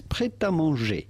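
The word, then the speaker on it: Prêt A Manger: In French, we would say this, prêt à manger.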